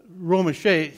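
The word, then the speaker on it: Romesha: Army Staff Sergeant by the name of Clinton Ramesha, I think that's how you say his name. Is that how you say it? Ramesha? I see you mouthing. Okay. Meshe? Romeshe. Romeshe, he received the Medal of Honor for his courageous act during an enemy attack in Afghanistan.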